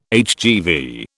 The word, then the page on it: HGV: British English